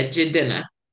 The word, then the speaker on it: Jidenna: I didn't know that's how you pronounce his name. – Yeah, Jidenna. And he says it in the song.